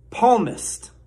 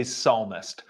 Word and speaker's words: psalmist: Now, clearly, he's trying to say psalmist from the book of Psalms in the Bible, which is kind of an honest mistake because it is spelled weird. That's not weird, but there's a P. You know, he should be used to having a silent P, as Jen Psaki is his press secretary, has a silent P at her in front of her name.